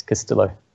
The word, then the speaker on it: Castillo: Yes, Castillo.